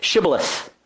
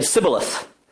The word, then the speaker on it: Shibboleth: And when he said, "No," they said to him, "Then say Shibboleth." And he said, "Sibboleth," for he could not pronounce it right.